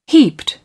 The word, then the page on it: heaped: British English